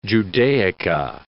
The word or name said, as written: Judaica